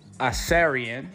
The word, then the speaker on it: Icarian: And this week's word of the week is Acarian. This week's word of the week is Acarian. The word is spelled I-C-A-R-I-A-N.